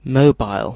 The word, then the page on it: mobile: British English